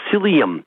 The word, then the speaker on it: psyllium: The one of choice is called psyllium, and it's one of those ones with a silent P, yeah, right. – as in football. So it's P-S-S-Y-L-I-U-M. Psyllium. P-S-Y-L-L-I-U-M. I think I spelled it wrongly. But if you just think silly, psyllium.